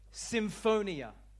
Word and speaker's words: symphonia: The word agree in the Greek word is pronounced this, symphonia. Symphonia is the Greek word for agree.